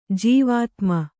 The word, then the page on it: ಜೀವಾತುಮ: jīvātuma